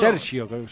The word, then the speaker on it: Sergio: ¿Se pronuncia así Sergio en italiano? – Sergio, ¿no? – Sí.